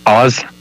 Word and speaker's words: Awes: Oz. But I'm Canadian, right? So you got that Canadian accent.